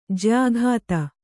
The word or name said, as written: ಜಯಗಾಥ